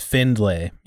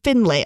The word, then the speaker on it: Findlay: Is it Findlay or Findlay? – It's Findlay. You got it. – That's what I thought. It's Findlay.